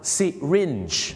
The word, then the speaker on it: syringe: ไซลิงหรือสลิงไซลิงเนี่ยนะครับเขาไม่ได้ออกเสียงว่าไซลิงนะครับไม่งั้นเดี๋ยวฝรั่งเขาจะนึกว่าคุณจะเอาเขาขึ้นสลิงห้อยโหนในโรงพยาบาลสิครับที่จริงแล้วนะครับคำว่าเข็มฉีดยาเขาออกเสียงว่า syringe